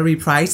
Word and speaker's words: reprise: reprise